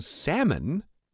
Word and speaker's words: salmon: This person now knows the L in salmon is silent.